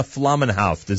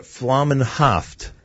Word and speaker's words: Flaumenhaft: Ms. Elana Flaumenhaft, is that the right pronunciation or not? – That's right, Nachum, you got it right. Thanks so much. – Haft or what, Flaumenhaft? – That's right.